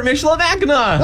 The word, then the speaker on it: Michilimackinac: That's, I just took a wild guess that it might not be the hard C on the end, that it might be the aw sound at the end. – Yeah, Fort Michilimackinac.